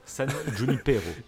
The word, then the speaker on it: San Junipero: San Junipero. oh, mais tu le dis si bien, c'est parfait, c'est parfait.